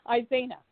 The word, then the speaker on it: Isena: So now, Isena, the first bit of work I'm concerned about, am I pronouncing your name correctly? – It sounds pretty good to me. Isena. – Okay. – That is, you're saying it perfectly.